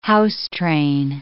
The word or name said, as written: house-train